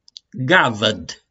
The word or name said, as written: GAVĂD